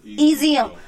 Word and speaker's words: EZIUM: Okay, so my, it's pronounced EZM, so I like the letters. – EZM.